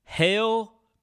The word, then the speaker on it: Heil: You were listening to me talk into a Heil PR40. H-E-I-L. I guess it's pronounced Heil. Kind of like Heil Hitler, which is a little disturbing.